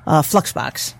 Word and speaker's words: Fluxbox: you can go ahead and just select Fox. – Oh, that's nice. – Fluxbox. Make sure I pronounce that correctly. – Yeah. Well, slip of the... – Don't forget that L.